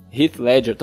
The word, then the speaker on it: Heath Ledger: É, principalmente o Coringa aí, não entregou nem, nem perto do, Rich, do Heath, Heath Ledger. Tô falando certo? Heath Ledger. Heath Ledger. Isso aí.